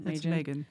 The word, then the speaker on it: Maygen: Is it Megan? – Megan. – Okay. Megan and the Bird Watcher, M-A-Y-G-E-N.